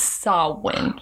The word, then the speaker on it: Samhain: But it's Samhain. – Like Saoirse. – Samhain. – And yes. – All those names. – Yes, Saoirse. – Gosh. – Yeah. So, Samhain.